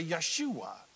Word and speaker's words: Yeshua: If you're a Hebrew-speaking person, you say Yeshua.